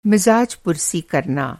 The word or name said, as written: मिज़ाजपुरसी